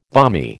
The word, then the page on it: balmy: American English